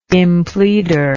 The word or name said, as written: impleader